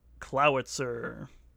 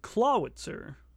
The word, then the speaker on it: Clawitzer: It evolves into a new Pokemon that we're gonna be talking about today, whose name is Clowitzer. Not Clawitzer. – Even though it's spelled that way. – It's Clowitzer. They wanna have their cake and eat it too with this portmanteau, basically. – Mm-hmm. – They wanna use claw but not pronounce it like claw.